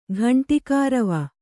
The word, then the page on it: ಘಂಟಿಕಾರವ: ghaṇṭikārava